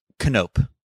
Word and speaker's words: Knope: Now, I believe if I'm correct, this is a Jordan Peele movie, the biography of Leslie Nope. From Parks and Recreation, another classic Universal hit show. – Knope. – Oh, okay. My bad. – Silent K. Yeah.